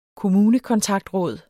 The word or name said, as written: kommunekontaktråd